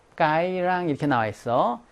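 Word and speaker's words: Cái Răng: 자, 이것이 남부 지역에서는 르 발음이 살아나요. 그래서 까이랑이라고 나와요. 우리 북부 지역에서는 즈야, 즈. 그런데 여기서 왜 까이랑이라고 했나? 까이랑이라고 하지 않고 이것의 위치가 남부에 있기 때문에 고유명사화 돼 버렸어. 이것이. 그래서 그냥 까이랑이라고 한 거야.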